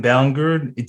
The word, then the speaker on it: Ballingrud: And Nathan Ballingrud.